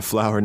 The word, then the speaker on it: flour: This sounds just like a rose, that kind of flour. Mm. The pronunciation is the same, but it's not. It's just a white powder. – And it's F-L-O-U-R. – Yeah. – Instead of O-W-E-R.